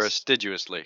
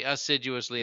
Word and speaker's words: assiduously: Assiduously or astiduously? Because I don't think assiduous is – It's actually assiduously, and yeah. I mispronounced it.